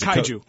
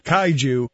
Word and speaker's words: Kaiju: Kaiju. – Uh-huh. – The Japanese... I came so close there to getting us in trouble. Kaiju. – Let's make it a soft J.